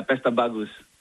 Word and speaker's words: Pesta Bagus: Pesta Magus, zeg ik dat goed? – Ja, Pesta Bagus.